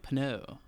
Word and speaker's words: Pinot: And I'm glad I said it right because if I tried to say another type of wine, like there's one that's P I N O T, and I always pronounce that wrong. – It's uh, Pinot. – See, I always think it's like Pinot. It, it's it's yeah, it's Pinot. But I went with Chardonnay.